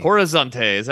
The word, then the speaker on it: Horizonte: Was checked into a hotel room in the heart of Belo Horizonte. Mm. Horizonte, is that how you say it? Yeah, where Jones was contacted by an American missionary named Ed Malman.